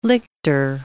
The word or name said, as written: lictor